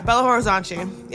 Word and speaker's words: Belo Horizonte: Yeah, Belo Horizonte.